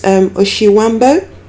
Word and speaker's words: Oshiwambo: Your language is Oshiwambo. Did I pronounce that correctly? – Yes.